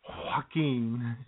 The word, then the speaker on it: Joaquin: No, you didn't say it right, J-Man. You got to do it with Joaquin. – Joaquin.